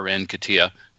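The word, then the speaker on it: Nketiah: Uh, you know, let, us give en- Enketia a break or Enkatia is my preferred pronunciation, but Enkatia break.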